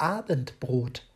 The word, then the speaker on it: Abendbrot: Die typisch deutschen Mahlzeiten in Deutschland sind ein ausgiebiges Frühstück, warmes Mittagessen und das Abendbrot. Hier liegt die Betonung auf Brot.